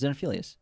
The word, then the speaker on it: Xenophilius: so gross, dude and then we get one of my favorite characters, Xenophilia. – Xenophilius? – Xenoph- Xenophilius? – Xenophilia. – Is it Xenophilius or Xenophilius? No, it's Xenophilius. – Oh, yeah, you're right.